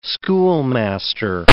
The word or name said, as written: schoolmaster